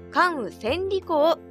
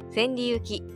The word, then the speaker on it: 関羽千里行: 関羽千里公と読みます千里行きでも千里行でもありません